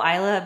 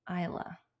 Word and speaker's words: Isla: My friend picked the name Isla, but it's spelled Isla. And when she first read it, she thought Isla, Isla. The S is silent.